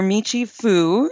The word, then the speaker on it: Michi Fu: Dr. Michi Fu.